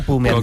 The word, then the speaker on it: Bu: Yun Chauquete Bu. – Se pronuncia Pu. – Como Chanquete, ¿no? – No tiene nada que ver con Chanquete.